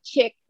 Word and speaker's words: Chic: And and so I also said, well, you know, did they tell the founder of Chick Jeans, which is spelled C-H-I-C, back in the 80s that he was pronouncing his brand wrong? No, it's chick.